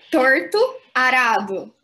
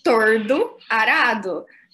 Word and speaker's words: Torto Arado: A Bia tá rindo porque o nome do livro é Torto Arado, do Itamar Vieira Júnior. Eu sempre falo tordo arado, como se fosse tipo Katniss Everdeen e.